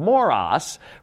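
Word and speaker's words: moros (μωρός): It's spelled this way in the Greek language, M-O-R-O-S, moros.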